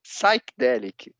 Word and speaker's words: psychedelic: E aí ela ficou revoltada comigo, porque ela falava que o pé era mudo. E ela falou, presta atenção, o pé é mudo. Não, e você cantando aí psychedelic, é psychedelic, não psychedelic.